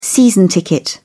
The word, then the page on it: season ticket: British English